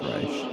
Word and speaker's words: Reich: People say Steve Reich a lot, but it's pronounced Reich. I ought to say, for the listeners out there, R-E-I-C-H, Steve Reich.